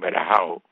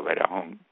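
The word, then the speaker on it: Varejão: Los Cavaliers trajeron a este muchacho brasilero, Verajón, que aquí le dicen Verajao, okay, pero se pronuncia Verajón.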